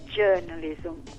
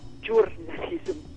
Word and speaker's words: journalism: Altro errore tipico è la pronuncia stessa del termine journalism, che in inglese è journalism, e non journalism, come spesso capita di sentire.